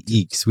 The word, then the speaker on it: Ickx: Ickx. – It's actually Ickx. We've been saying it wrong the whole time.